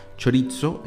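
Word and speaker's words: chorizo (χωρίζω): Sí, esa es una palabra que es chorizo, se pronuncia chorizo en griego, Y se refiere a eso, a separarse.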